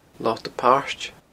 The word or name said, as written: Lota Pairt